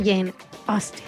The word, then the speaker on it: Jane Austen: Esta obra también que se une a las celebraciones de los 250 años de vida de Jane Austen.